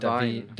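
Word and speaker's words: Da'Vine: Divine, Divine Joy Randolph, eh, också då från The Holdovers.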